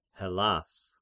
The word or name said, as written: Hellas